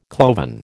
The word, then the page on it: cloven: American English